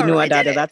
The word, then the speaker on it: Anua Dada: What inspired you to start the, and am I saying this right, Anua Dada Foundation? – Yes. Yep, oh, Anua Dada. You nailed it.